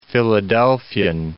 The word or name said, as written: Philadelphian